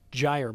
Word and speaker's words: gyre: Joel is nice enough to correct the pronunciation. Apparently the term is gyre, by the way.